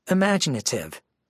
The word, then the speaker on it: imaginative: Imaginative. 5 syllables, main stress on the second syllable.